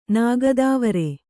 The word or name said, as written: ನಾಗದಾವರೆ